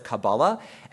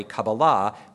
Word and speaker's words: Kabbalah: Now, they'll often pronounce it Kabbalah, and while I said that I use the more Israeli / Sephardi Kabbalah with the emphasis on the third syllable, Ashkenazi Jews did refer to it as Kabbalah.